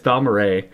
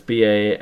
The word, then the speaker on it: Balmorhea: It's Balmora, but it's Balmorhea. And these guys are fantastic if you haven't kind of gotten into their discography at all.